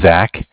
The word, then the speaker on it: Zach: My name is Zach, spelled Z-A-C-H. And I'm following up, I'm from Bank of Cardiff. Cardiff is spelled C-A-R-D-I-F-F.